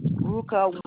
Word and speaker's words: Ruka: Ruka Wona. – Can you spell it for me? – R-U-K-A. – W-A-R-N-E-R.